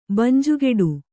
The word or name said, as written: ಬಂಜುಗೆಡು